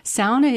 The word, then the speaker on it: sauna: And of course, sauna, which is based on the Finnish pronunciation in Swedish and Norwegian, too. And because of the lasting contact with Finnish and English in this part, the northwestern part of the UP, sauna is the local pronunciation for many people, instead of sauna.